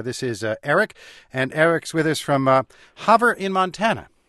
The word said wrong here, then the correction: Havre: Close enough. It's Havre, actually. – Havre. All right.